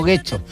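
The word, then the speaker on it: Getxo: No Guecho, no Guecho.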